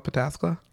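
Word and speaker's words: Pataskala: I'm from a town outside of Columbus called, it's spelled Patalaska, but it's called Patasqua.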